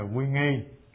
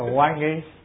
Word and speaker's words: quy nghi: Giới luật, hai là quy nghi. Chữ quy này đọc theo ngôn ngữ giọng Bắc, giọng Nam thì ta đọc là quan nghi, giọng Bắc là quy nghi.